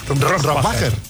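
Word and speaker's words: Rohrwacher: Rohrbacher.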